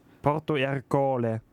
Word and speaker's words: Porto Ercole: Willst du nochmal die Aussprache kontrollieren? – Stimmt. Alles gut. – Porto Ercole.